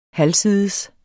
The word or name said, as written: halvsides